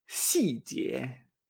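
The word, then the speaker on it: si jie (细节): Detalle en chino se dice si jie.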